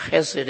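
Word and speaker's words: chesed (חסד): That Hebrew word, loving kindness, is H-E-S-E-D. It's pronounced chesed, and it means a loyal love.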